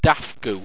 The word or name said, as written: dafgoo